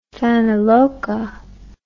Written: fanaloka

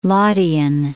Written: Laudian

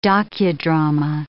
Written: docudrama

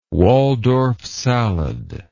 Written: waldorf salad

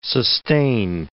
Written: sustain

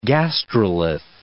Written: gastrolith

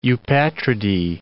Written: eupatridae

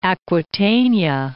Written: Aquitania

